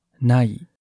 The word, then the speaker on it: ない: 使事物成为在日语里是一个动词，念作 nalu，不存在。在日语里是一个形容词，念作 nai，与汉语的没类似。日语的 nai 也是日语中各类否定结构的基本组成元素。要否定一个动词，就把动词词尾做点变化，然后接上 nai，比如 wakaru，明白， wakaranai，不明白。